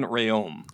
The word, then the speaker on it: Reum: Her last name is pronounced Rehome, but with a silent H. So it's Manon Reum.